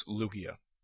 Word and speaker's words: Lugia: Lugia, not Lugia or Lugia. Some people go ahead and make it a soft G instead of making it a hard G, with the G in the Pokemon's name, Lugia. So it's Lugia.